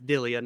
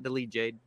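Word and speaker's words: Delija: Bruno Capiloza, Ante Dillia, I say his name wrong on Monday. It's Dillia, not Delije, Dillia, went to war, to say the least.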